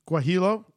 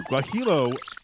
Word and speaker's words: Guajillo: Guajillo. – Thank you. Guajillo chilies, and radish honey.